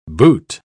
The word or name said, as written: boot